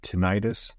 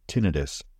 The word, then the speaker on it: tinnitus: Both tinnitus and tinnitus are the correct pronunciation, by the way.